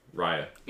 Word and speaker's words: Raya: It's spelled Raya, but it's Raya. – Mm-hmm. I feel like you spent like the whole time last time correcting him. Now, am I wrong? – Yeah, she spent the entire time last week correcting me because I was pronouncing it phonetically, how it was spelled. – Raya? – But it's Raya. Yeah, it's Raya spelled, but it's actually Raya in the film.